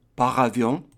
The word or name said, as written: par avion